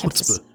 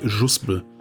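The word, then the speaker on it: Chuzpe: Wie ist denn die richtige Aussprache eigentlich? – Chuspe. Ja, also Chuspe, aber garantiert nicht Juspe.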